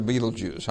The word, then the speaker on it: Betelgeuse: It is Betelgeuse. A lot of people pronounce it Betelgeuse, right? But it's actually Betelgeuse.